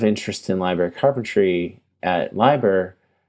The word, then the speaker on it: LIBER: I think one of the things that I learned recently at a library conference, Liber or Liber, I think is pronounced in French as Liber,